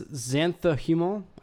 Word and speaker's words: xanthohumol: I have no idea how to pronounce this compound at all. It's xanthohumol, I think. God, there's going to be somebody that's like, I'm in science food right now. You fucking pronounce that wrong.